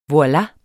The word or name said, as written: voila